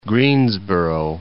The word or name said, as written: Greensboro